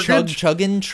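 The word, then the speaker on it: Chigurh: Anton, I can't pronounce his last name, Chergid? – It's like Chergin? – Chergin. – Yeah.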